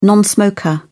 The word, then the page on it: non smoker: British English